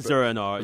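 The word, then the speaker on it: Zur-En-Arrh: And also to the Enzur. I can never pronounce it. – Zur-En-Arr. – Zur-En-Arr, right? – Yeah.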